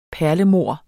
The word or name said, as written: perlemor